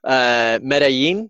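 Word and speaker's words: Medellín: And so Destination Dev, it's in Colombia, Medellin.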